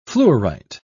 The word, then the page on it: fluorite: British English